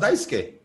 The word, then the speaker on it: Daisuke: His name is spelled Daisuke. So you'd probably read it Daisuke, but it's really pronounced Daisuke Matsusaka because that's the way it's supposed to be pronounced there.